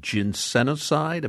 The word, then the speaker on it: ginsenoside: And Wisconsin ginseng contains a high level of ginsenoside.